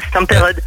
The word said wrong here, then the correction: Stampede: Ah bah non, c'est pas Stomperode, c'est Stampede.